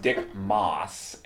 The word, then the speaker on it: Maas: And we're going to talk about a movie from a director whose name I think is pronounced Dick Maas, M-A-A-S, called The Lift.